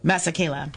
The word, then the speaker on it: Masekela: Hugh Masekela, I hope I'm saying that right. Masekela, thank you.